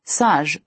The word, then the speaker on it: sage: In French, sage rhymes with the Taj in Taj Mahal.